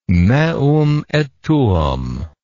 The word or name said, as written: meum et tuum